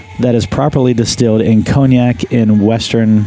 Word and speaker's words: Cognac: We're about to hear how it's pronounced. Oh, I've got to turn up my volume probably. Here we go. Let's try it again. – Cognac. – Cognac. Cognac. – So all we were close.